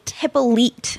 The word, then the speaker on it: Hippolyte: Hippolyte.